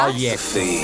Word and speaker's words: Project: Project.